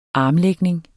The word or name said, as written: armlægning